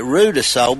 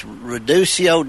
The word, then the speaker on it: Ruidoso: She mentioned she's in we call it Ruidoso, but that's incorrect pronunciation. But they have, I believe, horse races there, and there's different collectibles that come out of there. She might speak to the, if they have, it's Reducio Downs, I believe, but I think they have horse races out there, and I'm familiar with that.